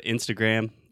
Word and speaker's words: Instagram: So Instagram, close your mouth. – Yes. Instagram. – Yeah, great, perfect. And all words that end in M or that have a syllable with M at the end of it, you have to close your mouth, right? – Right.